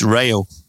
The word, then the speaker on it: Rayo: He said, it's not Rio, as in the Duran Duran song. It's Rayo.